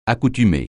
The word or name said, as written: accoutumer